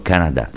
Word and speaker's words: Canada: con l'accento sull'ultima sillaba, come si usa in Francia, ovvero Canada. L'accento sulla prima sillaba da te rilevato e che sta guadagnando terreno in questi anni è conforme all'uso inglese e quindi da ritenersi anch'esso legittimo.